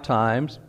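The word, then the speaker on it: time (τιμή): It's spelled like our word time, T-I-M-E. It's pronounced time, but it's a noun, and it means honor.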